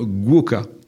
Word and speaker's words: gwoka: Mais on occulte trop souvent la culture guoka. Ça s'écrit G-W-O-K-A.